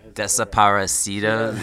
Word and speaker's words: Desaparecido: Despacito. Yeah, Desaparecido. Yeah, extra syllable in there.